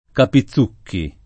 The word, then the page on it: Capizucchi: lingua italiana